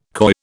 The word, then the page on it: coy: British English